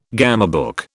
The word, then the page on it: gamebook: British English